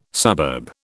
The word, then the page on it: suburb: British English